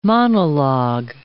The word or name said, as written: monologue